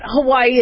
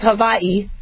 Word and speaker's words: Hawaii: And from what I understand, by the way, it's not Hawaii, it's Hawaii. It's supposed to be pronounced as Hawaii because the W is pronounced as a V.